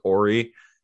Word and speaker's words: Horry: And I'm going to say Horry because he very much says that his name is Ori. And I'm going to go ahead and pronounce the H because he's an asshole. – I'm with you. – But yeah, Robert Horry threw him into the freaking score table, and you know, we ended up losing the series because of it.